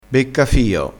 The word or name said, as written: beccafio